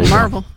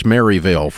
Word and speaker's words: Maryville: Well, Merville. – That's how you say it. That's how you say it. If you're a Yankee, it's Maryville for the rest of you.